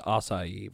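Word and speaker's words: açaí: I really like what they did with the Blueberry Akai demons. – The what? – The Kakko demons. – It's pronounced Acai, by the way.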